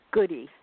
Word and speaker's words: Goode: Is it Goode or Goody? – Well, they did pronounce my name, but they pronounced it incorrectly. It's Goody.